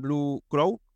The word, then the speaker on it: Blue Crow: Y, y bueno, aparece la oportunidad de, vincularte al, al, Club Deportivo Leganés más bien vincularte a, Blue Crow, que lo he dicho mal eh, la primera vez, eh, así que corrijo, haga la redundancia.